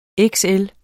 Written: XL

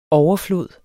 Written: overflod

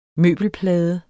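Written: møbelplade

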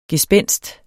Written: gespenst

